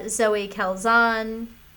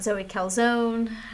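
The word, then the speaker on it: Zoe Kazan: Zoe Calzon, not Zoe Calzone, who...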